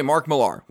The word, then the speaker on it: Millar: So, when I say his name, I say Mark Millar. When I'm talking about his division of Netflix, I call it Miller World.